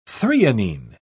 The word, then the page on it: threonine: British English